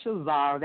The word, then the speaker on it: Shazara: My name is Shazara. That's spelled S H A Z like zebra A R A, and you can find me at Shazara seven.